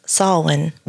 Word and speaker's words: Samhain: Even though it's spelled differently, it is in fact pronounced Samhain.